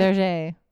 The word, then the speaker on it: Bergère: The Follies Bergère, right? Did I say that right? – Berger. – Oh, there you go. You got it.